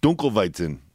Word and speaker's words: Dunkelweizen: Dunkelweizen. – God bless you. – Sam, how's my pronunciation? Yeah. – That sounded great.